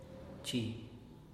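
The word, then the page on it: txi: Teotitlán del Valle Zapotec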